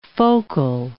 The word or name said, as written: focal